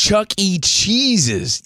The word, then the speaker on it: Chuck E. Cheese's: Dad, it's Chuck E. Cheese's.